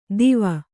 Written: ದೀವ

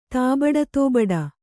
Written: ತಾಬಡತೋಬಡ

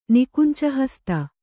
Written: ನಿಕುಂಚಹಸ್ತ

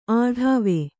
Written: ಅಡಾವೆ

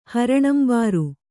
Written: ಹರಣಂಬಾರು

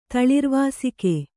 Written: ತಳಿರ್ವಾಸಿಕೆ

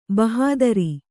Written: ಭಾಹದರು

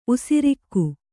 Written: ಉಸಿರಿಕ್ಕು

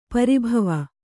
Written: ಪರಿಭಾವ

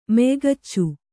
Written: ಮೆಯ್ಗಚ್ಚು

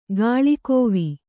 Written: ಗುಳಕವ್ವ

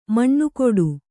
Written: ಮನಗೂಡು

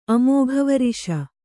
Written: ಅಮೋಘವರಿಷ

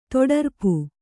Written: ತೊಡರ್ಪು

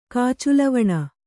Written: ಕಾಚುಲವಣ

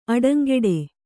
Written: ಅಡಂಗೆಡೆ

